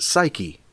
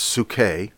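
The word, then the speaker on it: psuche (ψυχή): Secondly, the soul, psuche, the way it's spelled, we would pronounce it psyche, but in the Greek it's psuche.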